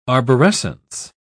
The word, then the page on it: arborescence: British English